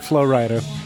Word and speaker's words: Flo Rida: White and Nerdy is like, because I heard it before I heard Flo Rida.